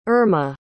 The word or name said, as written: Erma